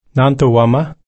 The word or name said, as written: nanto-womma